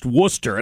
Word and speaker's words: Worcester: reminding me that it's pronounced Worcester.